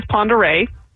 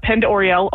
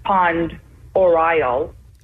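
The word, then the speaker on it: Pend Oreille: So our county is Ponderay. but we hear Penned Oriel a lot. – That's the only one I've ever heard on the mispronunciation is Penned Oriel, because that's the way it's spelled.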